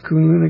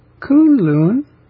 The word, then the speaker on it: Kunlun: It's pronounced Kunlun, something like that.